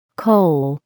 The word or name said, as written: coal